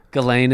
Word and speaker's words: Ghislaine: Ghislaine is how you pronounce Maxwell.